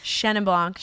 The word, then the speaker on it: Chenin Blanc: Wait, does all Shannon Blanc? – Shannon Doherty? – I'm going to learn how to say it. – Chenin Blanc come from Loire Valley.